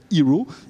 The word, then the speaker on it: Heraw: Un peu plus qu'une start-up qui s'appelle Hero. Et vous allez nous la présenter. – Ça s'écrit H-E-R-A-W.